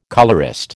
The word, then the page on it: colourist: American English